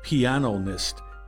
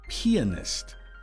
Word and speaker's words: pianist: It's not pianist, but pianist.